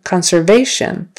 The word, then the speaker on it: conservation: Conservation. Conservation. Third syllable.